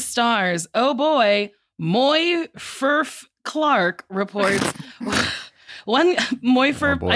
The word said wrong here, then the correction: Morfydd: Morfydd, but it's spelled M-O-R-F-Y-D-D.